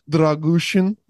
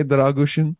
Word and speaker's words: Dragušin: But Radu Dragušin is the correct pronunciation, by the way, Dragušin.